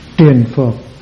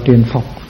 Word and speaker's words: triền phược: Sắc thọ tưởng hành thức triền phược, triền phược, ngoài bác đọc là triền phục,